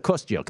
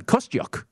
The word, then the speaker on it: Kostyuk: Yes, Marta Kostyuk. Thank you, Jeff. My goodness. – Pronounced that right? – You did. – Wow, that's very nicely done. Kostyuk.